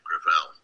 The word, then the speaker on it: Gravel: With all due respect, let me correct, I pronounce it within my family, Gravel. – Gravel? – It's a French name.